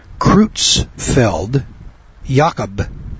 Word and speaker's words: Creutzfeldt-Jakob: It is pronounced Creutzfeldt-Jakob disease.